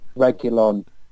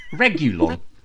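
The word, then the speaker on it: Reguilón: Regulon. Regulon. Is that right? – It's it's yes. Come on, Emma, say it. How do you say it? – No, no, he, he's actually right.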